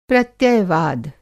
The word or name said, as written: प्रत्ययवाद